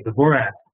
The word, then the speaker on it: Dvorak: and it's spelled D, and then V is in Victor, O-R-A-K, Dvorak.